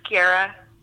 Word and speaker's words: Guerra: Uh, just Guerra.